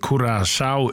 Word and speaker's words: Curaçao: Och vi kommer att säga att landarnavnet Curaçao uttalas Cura, K-U-R-A-S-A-O av vårskan Curaçao.